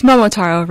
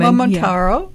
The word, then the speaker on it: Momotaro: chef and owner of Momotaro. Am I saying that right? – It's Momotaro Ramen. – Momotaro, yeah. Ramen.